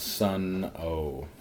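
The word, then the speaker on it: Sunn O))): That's pronounced Sun O, but they just use the Sun Amps logo.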